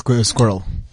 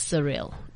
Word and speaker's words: Sxuirrel: It's just Squirrel. – Oh, it's Squirrel. – Squirrel, but there's an X. – Okay. So for our guests, it's actually spelled SXUIRREL. And I was pronouncing it as Surreal.